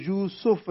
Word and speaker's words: یوسف: تو یوسف عام طور پر رکھا جاتا ہے، کہا جاتا ہے، لیکن قرآن مجید کا جو تلفظ ہے اس لفظ کا وہ یوسف ہے، سین کے اوپر جو ہے وہ پیش ہے، یوسف.